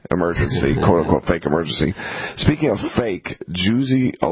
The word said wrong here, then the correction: Jussie: It's pronounced Jussie.